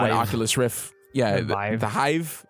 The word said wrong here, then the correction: Vive: yeah, is that what it's called? – No, Vive. – The Vive.